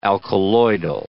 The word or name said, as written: alkaloidal